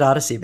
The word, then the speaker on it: Odyssey: The Luminaries with David Odyssey, baby.